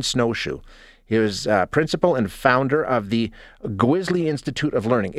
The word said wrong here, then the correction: Gwizhii: Did I say that correctly? Gwisley? – It's actually Gwiji. – Gwiji. Okay, I'm sorry. I'm sorry. – It's okay. It's spelled G-W-I-Z-H-I-I.